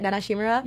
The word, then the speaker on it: Nana Shimura: But Nana Shimura? Is that how you say it? Nana Shimura? – Yeah.